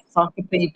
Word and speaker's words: Sacopee: Did I say that correctly? – Yes, Sacopee Valley High School. It's an Indian name. – Yes. – It's composed of Saco River and Ossipee River.